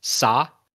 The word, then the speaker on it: Sa: Sa, the British pronunciation of sir, like yes, Sa, kind of thing.